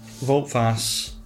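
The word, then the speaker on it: Wout Faes: Vote Face. – Yes. – The Belgian. – The Leicester City centre-half. – Yes. – Voltfas, Michael Hector. – There you go. Voltfas, like the French term.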